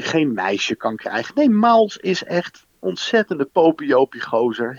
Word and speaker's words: Miles Morales: Dat is ene Miles, zeg ik dat goed? Jij bent helemaal, jij bent meer thuis in de Spider-Man en de superheldenfilms misschien dan ik. – Ja, Miles Morales.